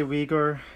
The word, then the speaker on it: Uyghur: Or how would you pronounce that in Uyghur? Uyghur? – Yeah, most people say Uyghur. In Uyghur, you say Uyghur, which is harder to say. Yeah. It's like a back sound, like ba.